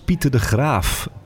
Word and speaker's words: Pieter de Graaf: maar ook aan tafel natuurlijk speciale gast Pieter de Graaf.